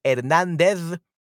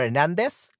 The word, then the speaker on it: Hernández: But Hernandez, okay, or Hernandez, like we say in Latin America, because we don't pronounce the Z sound, we pronounce it like S.